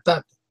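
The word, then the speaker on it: Ptah: Del dios supremo, que se pronuncia Tat, P-T-A, bueno, y H.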